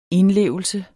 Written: indlevelse

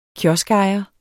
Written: kioskejer

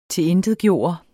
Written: TILINTETGJORTE

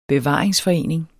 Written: bevaringsforening

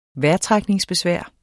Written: vejrtrækningsbesvær

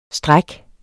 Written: stræk

